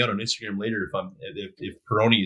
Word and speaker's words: Peroni: I want to say Peroni. Am I saying that right? – Peroni.